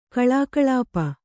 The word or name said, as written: ಕಲಾಕಲಾಪ